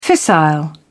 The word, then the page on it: fissile: British English